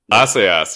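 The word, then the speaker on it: Assayas: Is it Asayas? Can we agree on that up front? – Asayas, yeah, is the pronunciation, is my understanding.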